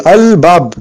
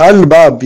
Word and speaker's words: al-bab: Et donc par exemple, quand on dit par exemple al-bab, vous voyez là, al-bab, yani le lam s'est resté intact quand il est rentré sur le bas. Al-bab, al-jamal, yani le lam, on doit le prononcer. Al-kamar, on doit le prononcer, notre lam. Al-fil, yani le lam, on le prononce.